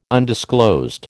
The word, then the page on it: undisclosed: American English